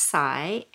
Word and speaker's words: Si: Yeah, my name's pronounced Cy, and so my full name is Sayuri, which a lot of people don't know.